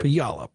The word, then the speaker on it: Puyallup: Puyallup, Washington on Coast to Coast. Am I pronouncing that right? I've always wondered. – Puyallup, Washington. – Puyallup. Okay, I was close enough.